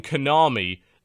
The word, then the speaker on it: Konami: So if I put in Konami, there is nothing. So you got to think of, okay, what rhymes with Konami? Salami.